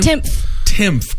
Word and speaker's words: Timpf: Timpf.